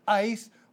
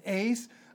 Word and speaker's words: eis: So, starting literally then, having therefore, brothers, confidence, and then we have the little particle, it's a preposition in Greek, E-I-S, eis, or some would pronounce it ace. I had a Greek scholar who said eis. Anyway, it's E-I-S in the Greek, and it means with reference to, or unto, or into.